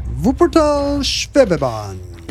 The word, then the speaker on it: Wuppertal Schwebebahn: Did I say that right? Wuppertal Schwebebahn. – Good job. – It's fun. – It is fun. You got the V right, and neither of us are going to do a very good German accent unless we do a comical one.